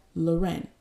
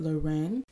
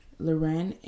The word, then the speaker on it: Lorraine: But if you know me in San Antonio, you know that I like to really go by how my parents say it, Lorraine.